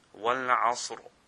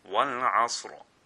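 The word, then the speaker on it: Wal-Asr (والعصر): If that letter has a Fatha, which is a Zabr, or it has a Pesh, then this Ra will be pronounced full mouth. And both of these examples are in Surah Al-Asr. Okay, so the first example, the example of Fatha, is Wal-Asr, Wal-Asr. Wal-Asr. The Ra is pronounced full mouth because the Ayn has a Fatha on it.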